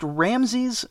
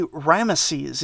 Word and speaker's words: Ramesses: And I should say, I always heard it pronounced Ramses, but apparently Ramesses is more correct.